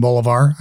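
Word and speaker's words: Bolívar: and by the name of Simon Bolivar, I guess would be the English pronunciation.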